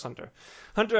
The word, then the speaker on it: Hunter x Hunter: It's apparently pronounced Hunter Hunter, even though there's an X in the middle, but whatever.